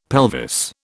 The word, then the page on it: pelvis: British English